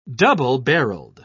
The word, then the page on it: double-barreled: British English